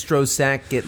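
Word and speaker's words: Stroszek: That character's name is Strocek. Am I saying that correctly? – I mean, it's Strozek, but I but when they say it, it sounds like Strocek.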